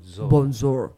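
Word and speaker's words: bonzour: It's B-O-N-Z-O-U-R. So bonjour. It does sound like it's a bit of Frenchish right there, but it's not. It's not bonjour, but it's bonjour.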